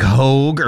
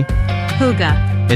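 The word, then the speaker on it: hygge: I thought it was like hoag or something, but apparently... – Hoga. – Is how you pronounce it.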